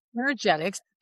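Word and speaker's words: Innergetics: The website that I have is Energetics, and I always have to spell it because it sounds like it's E-N, but it's inner, energetics, energy from within, because I'm always connecting to that place within that person.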